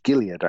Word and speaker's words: Gaillard: It's Gilead, actually, I think. Lamont Gilead. – Is it? Is it really? Is it Gilead? – Yeah. That's Gilead.